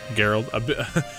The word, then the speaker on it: Geralt: Before its scarred, white-haired hero, how do you say it? – Geralt.